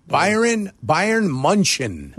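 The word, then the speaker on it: Bayern München: Is it pronounced Bayern Munich? – Bayern Munchen.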